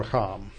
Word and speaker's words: racham: This verb is the verb racham. The verb racham or racham. Probably racham. Hebrew usually accents the last syllable.